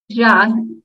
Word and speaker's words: Jha: Nicholas Scudder, Patrick Kiprop, and how did you pronounce the last one? Arjun Jha? – Jha. It's J-H-A.